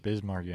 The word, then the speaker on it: Biz Markie: Number four, Just a Friend, Bix Markie. – Biz Markie.